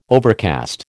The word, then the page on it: overcast: American English